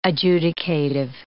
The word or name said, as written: adjudicative